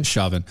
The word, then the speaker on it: Chauvin: Chauvin.